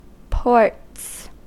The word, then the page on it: ports: American English